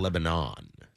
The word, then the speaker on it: Lebanon: He was actually born in Lebanon. And then it is pronounced Lebanon.